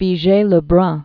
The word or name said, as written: Elisabeth Vigee-Lebrun